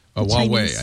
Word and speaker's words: Huawei: Huawei. I think it's Huawei, but I may be wrong. Huawei. Yeah. – Huawei. So they're all going to have devices right out of the gate, Huawei.